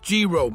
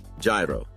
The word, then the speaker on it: Gyro: I think it's pronounced Giro. – Gyro.